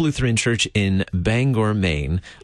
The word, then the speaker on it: Bangor: How do you pronounce it? – It's Bangor. – Bangor.